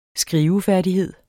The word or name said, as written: skrivefærdighed